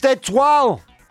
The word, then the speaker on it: étoile: It's pronounced étoile.